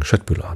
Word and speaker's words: Köttbullar: Tatsächlich kriegt man die immer als Köttbullar verkauft bei IKEA. Ich finde ja, dann könnte man mal die korrekte schwedische Aussprache beibringen. Köttbullar natürlich. Kött heißt Fleisch.